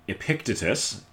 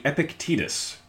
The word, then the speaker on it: Epictetus: But I've also only read the name, so I think we pronounce it as Epictetus. It is actually Epictetus.